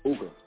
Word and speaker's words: Ooka: It's pronounced Ooka. O-O-K-A. It's a cool backwards.